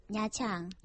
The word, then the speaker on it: Nha Trang: Am I saying that right? Nha Trang? – Nha Trang.